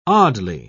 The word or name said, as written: oddly